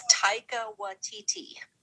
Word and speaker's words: Taika Waititi: It's Taika Waititi.